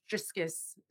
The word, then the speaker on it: Jiskus: It's actually actually pronounced Jiskus in Cree.